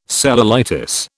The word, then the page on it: cellulitis: British English